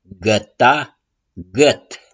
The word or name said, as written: GĂTA